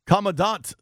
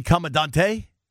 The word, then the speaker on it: Commandant: Commandant. That's how you say it. Commandant. – Would it not be Commandante for Commander Tequila? – I don't know. Let's ask him so we can make sure we write on that.